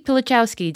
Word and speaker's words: Pilichowski: Uri Pilichowski, did I pronounce it correctly? – Perfect.